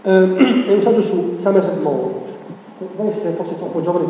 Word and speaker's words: Maugham: Somerset Maugham, si scrive così, si pronuncia Maugham, Somerset Maugham.